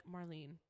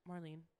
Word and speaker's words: Marlyn: And I'm like, Marlene. And she's like, what? I'm like, Marlene. And she's like, how do you spell that? I'm like, M-A-R-L-Y-N. And she's like, M-A-R-L-Y-N.